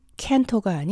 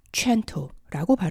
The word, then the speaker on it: cento: 라틴에서 백을 나타내는 centum, 캔텀은 이탈리아어에서는 C-E-N-T-O, 켄토가 아닌 첸토라고 발음합니다.